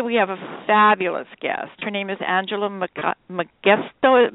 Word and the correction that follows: Megasko: Megasko. Megasko.